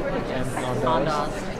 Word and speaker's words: Andaz: I've seen a lot of ballrooms like this, and the inside of the Andaz Hotel, I believe you pronounce it. Is that how you pronounce it? – Andaz.